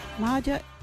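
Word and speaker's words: Maaja: Now, like a wedgie word is Maja. What is Maja? Maja is boss.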